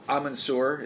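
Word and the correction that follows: Abin Sur: is that how you say it? – Abansur is the one who defeated Parallax the first time, right?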